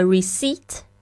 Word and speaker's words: receipt: May I have a receipt? 这个 receipt 虽然它这个拼法是 receipt，但是呢那个 p 是不发音的。